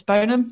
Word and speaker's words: Burnham: Uh, is Burnham? Is that how you say it? Yeah, Andy i Burnham.